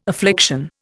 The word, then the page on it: affliction: Indian